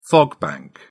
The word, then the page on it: fog bank: British English